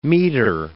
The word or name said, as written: metre